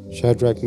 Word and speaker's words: Shadrach: When Pastor Lee started the meeting and he said, this feels like Shadrach, Meshach and Abednego.